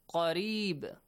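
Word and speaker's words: قريب: وَإِذَا ذَال, pronounce it correctly, سَأَ لَكَ Hamza, عِبَادِي عَيْن, عَنِّي again عَيْن, فَإِنِّي Hamza, قَرِيب قَاف, don't pronounce it like kaf, it's different.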